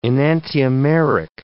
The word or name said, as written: enantiomeric